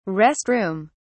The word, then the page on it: rest room: British English